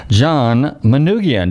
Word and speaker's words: John Manoogian: I'm delighted to welcome to the show this evening, John Manoogian.